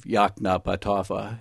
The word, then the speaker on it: Yoknapatawpha: One of the characters in the novel, Gavin Stevens, drives out of Yoknapatawpha, as he taught us how to pronounce it, and looks back on the town and the county.